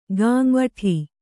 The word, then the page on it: ಗಾಂವಠೀ: gām`vaṭhī